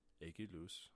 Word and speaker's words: Eguiluz: If you are not subscribed, my YouTube channel is my name, Daniel Aguiluz. My last name is a little tricky, but it's spelled E-G-U-I-L-U-Z.